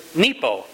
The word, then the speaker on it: nipo: And the word is nipo. It's a small four-letter word where the I is pronounced like a long E.